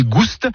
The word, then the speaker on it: gouste: Ni goût ni gouste, avec prononciation du S originel que l'on retrouve dans le provençal gustus, goûteux, et qui a été remplacé depuis par l'accent circonflexe dans notre orthographe moderne.